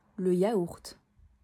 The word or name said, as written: Le yaourt